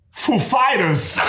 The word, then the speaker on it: Foo Fighters: And he comes up and he's like, he asked us if the accent was on Foo or Fighters. And we know who he is. Like, of course, we know how he speaks. And we said, the accent is on Fighters, actually.